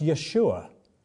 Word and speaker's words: Yeshua: It's pronounced Yeshua.